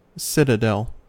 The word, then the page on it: citadel: American English